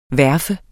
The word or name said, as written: verfe